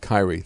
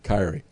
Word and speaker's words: Kyrie: Oh, yeah, you better mandate the vaccine because, it's pronounced Kyrie. Thank you, Travis. See, I don't know. I don't follow basketball. Thank you for letting me know it's pronounced Kyrie.